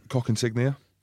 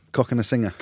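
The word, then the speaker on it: Cokanasiga: Joe Cock Insignia. – It's not Cock Insignia. – How do you say it? – Cock and a singer.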